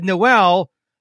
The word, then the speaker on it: Noel: And you said Noel, like pronounced like the song The First Noel.